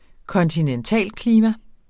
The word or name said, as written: kontinentalklima